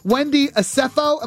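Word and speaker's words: Wendy Osefo: And question five, Wendy Acefo, am I saying that right? – Yes, you are.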